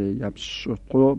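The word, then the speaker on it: يبسط: در یبسطو ای که سینه است و چون بعدش تن واقع شده هیچ فاصله هم بین سین و تن نیست، این سین یبسطو بدل به ساد شده، یبسطو با ساد تلفظ میشه.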